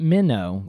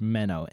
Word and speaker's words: Menno: And but either way, Menno, Brother Menno, and not Minnow like the little fish, Menno, M-E-N-N-O.